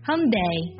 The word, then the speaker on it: Hyundai: 其实它正确的发音是 Hyundai Hyundai，我听起来还是有点像混蛋是吗？但是它是不一样的 Day Hyundai。